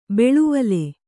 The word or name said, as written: ಬಾಳ್ವಲಿ